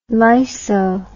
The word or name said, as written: lyso